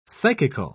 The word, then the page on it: psychical: British English